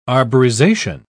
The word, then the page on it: arborization: British English